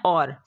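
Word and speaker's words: hour: इसके बाद है और, इसको हॉर नहीं पढ़ना, इसको पढ़ना है और।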